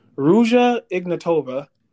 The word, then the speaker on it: Ruja Ignatova: But in 2014, a woman named, oh my gosh, I'm going to butcher this, Ruja Ignatova.